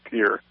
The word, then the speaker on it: Pierre: That's Fort Pierre or Pierce? – Pierre. It's spelled Pierre, like yeah, the French Pierre, but they pronounce it Pierre.